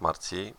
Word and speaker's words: Marcji: Poruszę tu też temat Marcji, bo tak się to wymawia, a przynajmniej taka wymowa wynikła z reportażu TVP Kultura, gdzie były prezentowane fragmenty spotkania z twórcami.